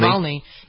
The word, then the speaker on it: Navalny: Is it Navalny? I always wonder about pronouncing these names. That's it, right? Navalny? – Navalny. Yeah, but he's the guy who recovered from being poisoned.